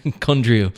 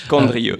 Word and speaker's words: Condrieu: Say that again. – Absolutely. – Yeah, Condrieu. – Condrieu. – Condrieu. Yes. A lot of mistakes around the pronunciation of that, but you heard it here, folks, on Another Bottle Down.